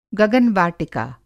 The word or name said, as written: गगन-वाटिका